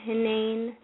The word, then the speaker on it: Hanane: Well, it's Miriam with an M at the M at the end, and Hanane, it's an Egyptian last name.